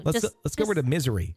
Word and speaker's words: Missouri: And it's pronounced more like misery instead of Missouri.